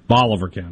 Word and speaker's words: Bolivar: if you're not in Bolivar County, not Bolivar. Am I saying it right now, Bolivar? – Bolivar County. – Bolivar County.